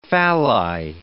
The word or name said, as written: phalli